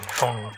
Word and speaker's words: Phaune: I mean, phone radio. It's spelled P-H-A-U-N-E, but it's pronounced phone.